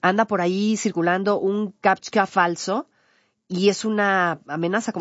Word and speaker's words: CAPTCHA: CAPTCHA, perdóneme, CAPTCHA, lo dije mal.